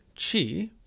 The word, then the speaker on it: qi: In traditional Chinese culture, qi, pronounced qi, C-H-E-E, also called qi in some traditions, is believed to be a vital force forming part of any living entity.